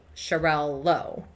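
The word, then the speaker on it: Xarel·lo: So, it's XARELLO. Xarello. But in other languages, this is either replaced with a hyphen or removed entirely. But either way, it's pronounced Xarello.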